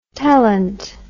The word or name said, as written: telint